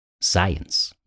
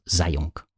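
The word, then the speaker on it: Eysenck: But this is like a thing that you have to hear from somebody, that you're supposed to pronounce his name Science, not Zayunk, which is how you would sort of pronounce it Science.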